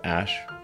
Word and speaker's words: Æ: Um, so, I mean, it's just X, the letter X, um, and then the AE is like pronounced ash.